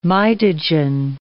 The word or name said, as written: mitogen